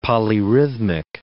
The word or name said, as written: polyrhythmic